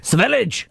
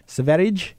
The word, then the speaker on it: Sverige: Sverige. – Sverige.